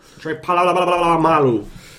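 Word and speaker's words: Polamalu: Odd tribute to Palomalu. That's how you pronounce it, right? – Yeah. – Palomalu.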